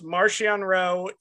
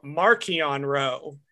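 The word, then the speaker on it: Marchion Ro: So he's pronounced Martian Rowe in Light of the Jedi and Rising Storm, and then literally the same guy, Mark Thompson, switches to Markion Rowe in Fallen Star.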